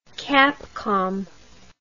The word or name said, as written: Capcom